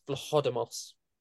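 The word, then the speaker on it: Vlachodimos: Yeah, I've heard it a few ways. I've seen a guy I know on Twitter who is Greek himself saying it's Vlahodimos. – Vlahodimos.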